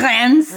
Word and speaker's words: Rennes: And the university, I'm going to butcher this, I'm so sorry. It's in France, but Université de Rennes. – Rennes, there you go.